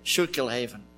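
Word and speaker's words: Schuylkill Haven: I hope I'm pronouncing it Schuylkillhaven. Okay. – You can get that. That's pretty good.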